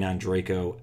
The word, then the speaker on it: Ondrako: as well as at Brian Ondraco on Instagram and Twitter. Again, the last name is spelled O-N-D-R-A-K-O.